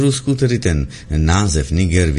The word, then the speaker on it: Niger: Když se podíváme zkrátka kamkoliv, tak uvidíme, že nezávisle na tom, zda šlo o dobu Sovětského svazu nebo současného Ruska, je správná výslovnost s přízvukem na první slavice, tedy Niger.